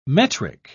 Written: metric

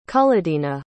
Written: cullodina

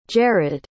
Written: Jerrod

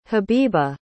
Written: habiba

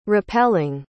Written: repelling

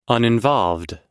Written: uninvolved

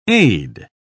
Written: aid

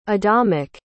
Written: adamic

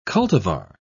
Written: cultivar

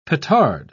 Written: petard

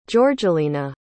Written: jorgelina

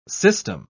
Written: system

